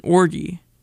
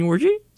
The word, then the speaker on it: orgy: With a hard G. Orgy, yeah. And then someone else, you know, and this is eighth grade, you know, ruthless, really, was like, oh, you mean orgy?